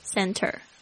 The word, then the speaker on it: center: R-E-C-R-E-A-T-I-O-N Recreation Recreation center 或者是有时候念得很快会听成 center 那个 T 就不发出来，这个 T 是在 N 后面会有一个这样潜规则哦，念得很快的时候 T 就不发音了， center 这个英式的拼法呢可能会拼成 C-E-N-T-R-E，大家稍微注意一下，嗯，说法都是一样的 center OK。